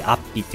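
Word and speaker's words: 安比: あの、安全の安に比率の比って書いてアッピって読むんですけれども、アイヌ語で安住の地っていう意味らしいです。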